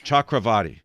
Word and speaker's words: Chakravorti: Chakravarti.